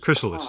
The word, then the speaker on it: chrysalis: They call it chrysalite or something. – Chrysalis, I think, is the Thank you. Thank you. That's the right pronunciation, chrysalis.